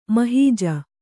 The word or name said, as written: ಮಹೀಜ